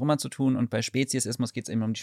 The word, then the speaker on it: Speziesismus: Ist es richtig ausgesprochen? – Ja, Speziesismus. – Ich dachte, es heißt die ganze Zeit Speziesismus, aber es heißt Speziesismus.